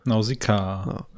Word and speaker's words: Nausicaä: Bei 7 Nausicaä oder Nausicaä, wie hast du es ausgesprochen? Einfach Nausicaä. Ne? – Nausicaä. Ja.